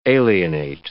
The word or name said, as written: alienate